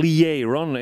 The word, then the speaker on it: Lee Aaron: Lee Aaron, som man sa i Tynnered på 80-talet. Men Lee Aaron är väl kanske ett mer korrekt uttal av denna fantastiska sångerskas namn.